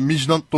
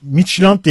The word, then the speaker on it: Midtjylland: Mijnant'ın telaffuzu nasıldır? Abi? Mijnant doğru bir telaffuz mudur? – Ben Midtjylland diye biliyordum. – Midtjylland galiba.